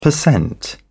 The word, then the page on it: percent: British English